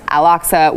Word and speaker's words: Al-Aqsa: Al-Aqsa was attacked.